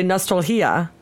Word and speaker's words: nostalgia: Wait, how do you say Nostalgia in Spanish? – I mean, if you were to pronounce it phonetically, Nostalgia. – Oh, because the G is a he. – Yeah, is a H sound.